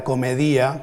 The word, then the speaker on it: comedia: por la escansión hay que pronunciar comedia, que era palabra para entonces culta, pronunciado a la griega, ese es el único título que Dante reconocería como definidor de su obra.